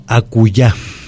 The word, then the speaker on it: acullá: Vamos a hablar de la palabra acullá. – Acullá, con doble L y acento L, en la A. – Con doble L y con acento en la A, en la última A.